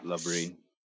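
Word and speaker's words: LaBrie: and James LeBrie, or how do you probably say his name? I'm sorry, James. – Yeah, LeBrie.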